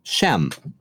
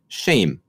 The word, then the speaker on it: Shem: So this is the genealogy of, as we pronounce it, Shem. But as a Hebrew speaker would pronounce it, shame. It has nothing to do with the English word for shame. It's just that's how they would pronounce the letters S-H-E-M, shame.